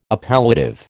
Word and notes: appellative: American English

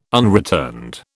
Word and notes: unreturned: British English

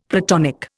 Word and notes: Platonic: Indian

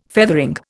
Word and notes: feathering: Indian

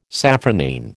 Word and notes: safranine: American English

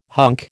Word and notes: hunk: American English

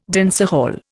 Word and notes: dancehall: Indian

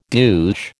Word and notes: douche: American English

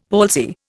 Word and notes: palsy: Indian

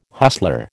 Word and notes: hustler: American English

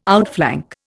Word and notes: outflank: Indian

